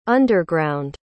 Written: underground

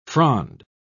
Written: frond

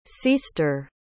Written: ceaster

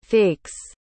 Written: fakes

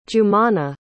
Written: jumana

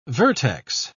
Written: vertex

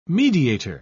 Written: mediator

